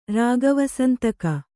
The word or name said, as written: ರಾಗವಸಂತಕ